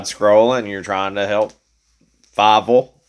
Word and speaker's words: Feivel: You're putting an F on it. You're calling him Feivel. It's Feivel. L.